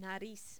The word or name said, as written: nariz